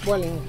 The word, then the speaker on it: Vueling: Vueling? – Vueling, yeah.